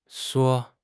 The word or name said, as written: shui4